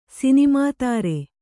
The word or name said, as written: ಸಿನಿಮಾತಾರೆ